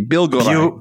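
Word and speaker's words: Biłgoraj: Bilgoraj. – Bilgoraj. – No L sound. – Okay, I'm just going to say it, Bill Garage.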